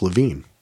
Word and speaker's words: Levien: And they actually have a Billions podcast with the guys who do it, uh, Koppelman and uh, David Levine, who spells his name L E V I E N, but it's pronounced Levine.